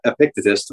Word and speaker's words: Epictetus: It's Epictetus or Epictetus, depending on what you prefer.